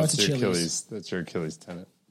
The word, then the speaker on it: Achilles: Pr- I'm gonna we're gonna find out how to pronounce this shit. Okay. Achilles. Uh, we were. It was Achilles. Achilles. Yep. So Billy was right.